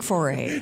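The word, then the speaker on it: Fauré: I like foray. – Thank you. Thank you. – Well, thank you. – She said it correctly.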